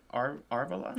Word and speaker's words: Arvala: It's Arvala 7. That's how you say it? Arvala? – Sure.